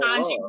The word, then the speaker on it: Ketanji: Judge Kenyatta Brown Jackson, it's all Ketanji Brown Jackson.